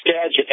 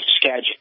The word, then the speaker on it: Skagit: It's Skagit as a J, but it's spelled with a G. Skagit.